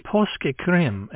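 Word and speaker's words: Påskekrim: Right, Pasque Cream, as I've heard it pronounced, literally Easter crime. P-A with a circle over the top, S-K-E-K-R-I-M, Pasque Cream.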